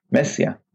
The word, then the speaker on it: Mesia: Messia, in the correct pronunciation in Romania is Messia.